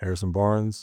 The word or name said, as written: Harrison Barnes